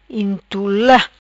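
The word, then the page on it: Intula: Patuá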